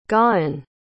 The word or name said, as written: gawen